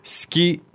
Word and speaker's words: 好き: 好き is written S-U-K-I. But how do we pronounce it?